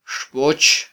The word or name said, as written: xboch